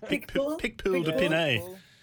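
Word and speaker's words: Picpoul de Pinet: The Picapool, okay, how do I pronounce that? – Well, you're nearly there. – Picpoul de Pinay.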